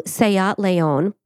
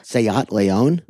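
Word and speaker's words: SEAT León: as well as a blue Seat Leon belonging to another one of the accomplices that was there.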